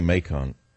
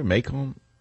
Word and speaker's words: Macon: I said it wrong. I can't say Macon. It's because I speak too much French. It's Macon in France. It's a very good wine, incidentally. How do you say it? It's Mason, Georgia? Macon? Macon. Why don't they spell it that way? M-A-K-E-O-N. Why not phonetic? I know. I'd have to spell it M-A-C-O-N and trip up guys like me from the north.